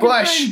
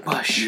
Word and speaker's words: Busch: It says it right on the fucking Bush. – Thing. – We have the beer that is spelled B-U-S-C-H. Bush.